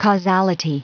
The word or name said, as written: causality